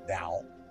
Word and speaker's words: Tao: Are we talking about the yin-yang principle like the Taoist, spelled with the T-A-T-O-A-I, I don't know, yes? Same with Taoism, but it's pronounced Tao.